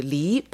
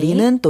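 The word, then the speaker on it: 리 (梨): 그 이 과일 중에서 배는 그 리 발음이잖아요. 네. 리는 또 떠나다는 리 발음 똑같은 거고요.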